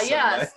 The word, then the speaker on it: Soleil: Soleil is spelled S-O-L-E-I-L, and it means sun. So Southern Sun, a lot of people don't know how to say it, but you will know how to say it.